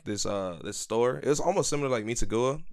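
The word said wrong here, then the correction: Mitsuwa: or whatever it's pronounced. – Mitsua.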